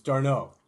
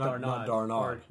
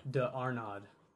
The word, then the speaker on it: d'Arnaud: Yes, it's Darno. – Not Darnard. – D'Arnaud.